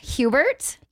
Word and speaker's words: Hubert: So today I have brought in Lauren Hubert.